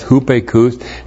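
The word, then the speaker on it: hupekous (ὑπήκοος): The conditional particle ae means if, to see whether, and then hupekus with the verb to be, hupekus with the verb to be. This is kind of a goofy word. H U P long E K O O S, hupekous,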